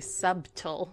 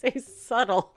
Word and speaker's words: subtle: Yeah, but none of us actually say subtle. We say subtle.